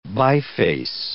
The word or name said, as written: biface